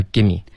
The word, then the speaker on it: gimme: 对啊，就发 give me，而且写法也变成了 g i m m e。